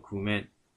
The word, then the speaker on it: 工面: 这两个字叫念作 “kuman”，它就是筹措啊、准备的意思。